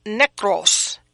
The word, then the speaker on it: nekros: And it is nekros, N-E-K-R-O-S, and it means death, like a physical dead, a dead body, or to be spiritually dead. So that's what I have, and I'm spelling it N-E-K-R-O-S.